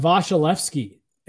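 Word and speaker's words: Vasilevskiy: Well, because AJ and I guess I don't want to speak for, I've always said Vasilevsky, and people this year started like making fun of me. I'm like, I feel like that's the correct pronunciation.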